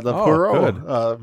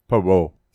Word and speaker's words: Poirot: Agatha Christie, the Poirot mysteries. I don't care that I pronounced it wrong. – Poirot.